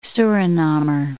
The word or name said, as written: Surinamer